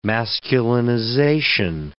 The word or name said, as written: masculinization